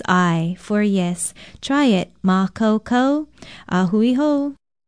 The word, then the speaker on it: makaukau: Aloha, I'm Lilani Poliahu of Hawaii Public Radio, and our Hawaiian word for today is makaukau. It is spelled M-A-K-A-U-K-A-U with a stress over the first vowel, and it means able, competent, prepared.